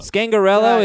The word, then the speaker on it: Scangarello: Is it Scangarello or Scangarello? – Scangarello is what I saw phonetically in the article I read about him today.